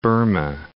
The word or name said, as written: Burma